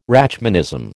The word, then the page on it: Rachmanism: American English